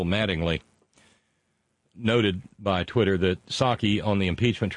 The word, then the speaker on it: Psaki: Yeah, because Jen Psaki, you don't pronounce the P, P-S-A-K-I.